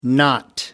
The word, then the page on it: not: American English